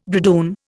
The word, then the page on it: bridoon: Indian